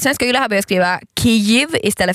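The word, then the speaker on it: Kyjiv: Sen har vi Kyiv, betoning på första stavelsen.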